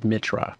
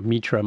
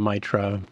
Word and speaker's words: Mitra: Pronounced with a short, what's it called, long I, when you say the I, and then I get all kinds of, you know, Russian pronunciations and stuff like that, but nobody ever says it correctly, which is Mitra.